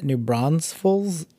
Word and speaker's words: New Braunfels: I know people that live in New Braunfels and for whatever reason put an S in the middle and pronounce it New Braunfels, and it is like nails on a chalkboard with somebody tapping a pen while waiting on you. It's the worst sound, and I'm always giving people a hard time when they live in town when they pronounce it like that.